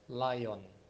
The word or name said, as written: Layon